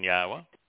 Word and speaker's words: Yahweh: And there is no trick to pronouncing Yahweh. He told Moshe that it was based upon the verb haya. Well, haya tells you how to pronounce the yah, doesn't it? – Mm-hmm. – H-A-Y-A-H.